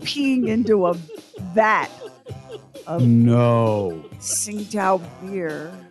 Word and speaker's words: Tsingtao: Lamar, let me make sure I say this right. It's the Chinese spirit Tsingtao. Is that how you say that? – Yes, that's it.